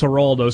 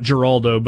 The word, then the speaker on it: Geraldo: Uh, so it was Geraldo. Somebody said the J is silent, Rippy, not Geraldo, but huh, Geraldo.